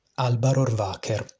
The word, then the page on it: Alba Rohrwacher: Italian; Male voice; Native speaker from North